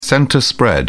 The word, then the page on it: centre spread: British English